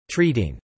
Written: treating

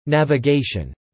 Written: Navigation

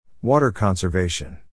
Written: water conservation

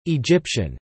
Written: Egyptian

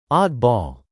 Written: oddball

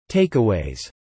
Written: takeaways

